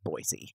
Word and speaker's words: Boise: The only thing inaccurate that you just said is out in Idaho, they pronounce it Boise. – What did I say? – Boise. Boise. – What did you say? – With an S instead of a Z. Boise.